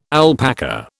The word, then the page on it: alpaca: British English